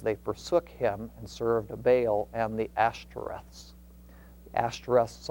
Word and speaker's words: Baal: I understand that the more technical pronunciation, probably more accurate, is Baal. Okay, but the more common use pronunciation is Baal, and that's even what the dictionary has as the pronunciation. So for our purposes, we'll use the Baals.